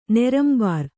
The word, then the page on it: ನೆರಂಬರ್: kannada